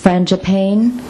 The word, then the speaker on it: Frangipane: Have you heard of Francis Frangipane? I don't know if that's how you pronounce his last name. Frangipane? Payne? Panny? Yeah, Panny. Okay.